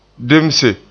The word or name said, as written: demse